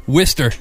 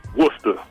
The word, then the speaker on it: Worcester: Is it Worcester or do y'all just say Worcester? – Worcester. – You gonna laugh. We pronounce it Worcester. – Worcester. Worcester, Mass. – Like it's Woosta.